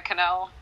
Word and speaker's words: Kanell: Jenna Cannell, KANELL is my last name.